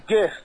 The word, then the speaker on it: GIF: It's a hard G. – Okay. – It's a GIF.